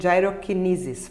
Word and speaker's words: gyrokinesis: E além de tudo, ela é doula e master trainer em gyrokinesis. Falei certo? Falou.